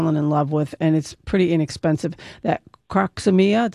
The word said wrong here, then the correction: Crocosmia: Did I say that right? Croxemia? – Crocosmia. – Crocosmia.